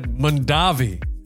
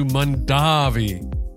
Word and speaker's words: Mondavi: Peter said Mondavi, the Americanized version their father had used. Robert switched to Mondavi, the traditional Italian pronunciation.